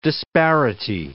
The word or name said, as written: disparity